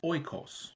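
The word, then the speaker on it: oikos (οἶκος): Because when referring to household, the Greek word is pronounced oikos, and it is a dwelling, a home, a house, a household, and it can imply a family, a family.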